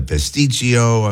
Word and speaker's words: pasticcio: There's the pasticcio, if I'm even saying that right. – Yes, you are.